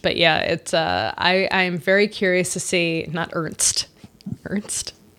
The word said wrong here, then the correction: Ernest: I just, you know, I'm letting you enjoy correcting my pronunciation. – Can I have my moment? – Yeah. – To say something correctly? – Ernest. Soak in it.